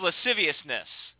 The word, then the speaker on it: lasciviousness: Unbeknownst to everybody else, they've crept in and they preach the gospel of lasciviousness. Is that how you say that word, Brother Nate? Did I say that right? – Yeah, I think you nailed it.